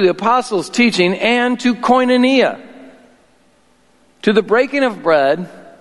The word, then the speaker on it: koinonia: That's called, it's pronounced koinonia. You can Google it if, if you struggle with that.